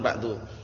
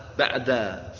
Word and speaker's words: ba'da: antum bacanya jangan qablu jangan ba'du qabla sama ba'da. Faham? itu perlu diperhatikan baik-baik. Qabla darsi, qablu darsi enggak boleh. Ba'du darsi enggak boleh. Untuk saat ini enggak boleh ya. Jadi ba'da qabla.